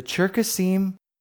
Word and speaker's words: Cherkasim: Cherkasim is the Hebrew pronunciation. Circassian is the English pronunciation. The Cherkasim use their own word to identify themselves in their own language.